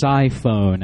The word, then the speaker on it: Psiphon: So it's Siphon. I was pronouncing it wrong all this time. P-S-I-P-H-O-N.